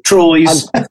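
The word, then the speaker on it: Troyes: Troy's. – Troy's, and it's probably Trois or something like that, but you don't want, of course.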